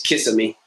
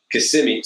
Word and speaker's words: Kissimmee: Often pronounces Kissimmee, but Kissimmee.